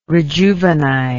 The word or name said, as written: rejuvenize